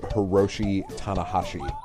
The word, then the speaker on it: Hiroshi Tanahashi: By the way, Chris, his name is pronounced Hiroshi Tanahashi. – Cool, I got that right. – Okay, there you go. – New Japan Pro Wrestling veteran Hiroshi Tanahashi showed up and won G1 Climax 28 or 28.